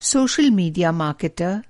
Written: मार्केटर

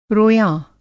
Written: रोयाँ

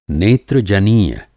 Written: नेत्रजनीय

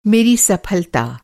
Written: सफलता

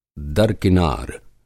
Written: दरकिनार

